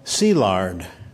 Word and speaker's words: Szilard: In Hungarian, S is SH, as in Budapest. SZ is S, as in Franz Liszt. And I is E. Leo is pronounced Leo, and Szilard, Szilard.